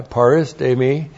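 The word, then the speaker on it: parastemi (παρίστημι): That one to whom, and then we have paristemi again, and that is the same verb we had in verse 13. P-A-R-I-S-T long E M I parastemi.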